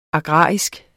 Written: agrar

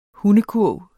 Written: hundekurv

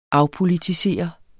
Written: afpolitisere

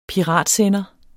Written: PIRATSENDER

